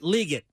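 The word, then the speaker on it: Legette: It is pronounced Xavier Leggett, not Leggett. Or not Leggett, it's Leggett.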